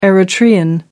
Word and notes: eritrean: American English

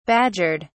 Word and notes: badgered: British English

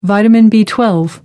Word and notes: vitamin b12: American English

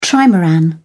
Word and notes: trimaran: British English

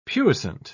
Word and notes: puissant: British English

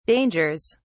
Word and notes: dangers: British English

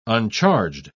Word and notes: uncharged: British English

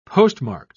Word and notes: postmarked: British English

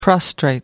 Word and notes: prostrate: American English

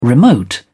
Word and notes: remote: British English

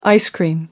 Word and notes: ice cream: American English

